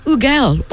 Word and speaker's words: Hugel: So his name is spelled H-U-G-E-L, and he has accepted that every different country calls it a different way. So you can call him Ugel, or as he has said, the English call him Hugel.